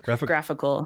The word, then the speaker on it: GraphiQL: How do you pronounce it? Graphical? – Graphical. – GraphQL? – Because I've pronounced it wrong a lot. not. So straight from the source, graphical.